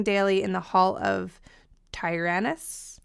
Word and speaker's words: Tyrannus: Yeah, I would say Tyrannus.